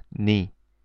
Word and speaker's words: 네: Next, 내, 내, your, and it's pronounced 니.